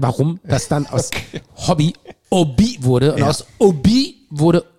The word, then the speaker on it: Obi: Obi. – Und der, die können ja das H nicht aussprechen. Ja. Ne? Die sagen ja statt ist stumm. – Statt Hobby sagen die ja Obi.